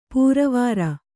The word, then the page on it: ಪೊರವಾರ್: Kannada